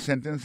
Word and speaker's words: sentence: Sa nou tak a di nan sentence, se ke aksan tombe sou sen nan, sou premier silab la.